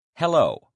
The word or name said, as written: hello